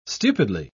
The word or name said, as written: stupidly